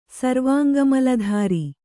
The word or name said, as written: ಸರ್ವಾಂಗಮಲಧಾರಿ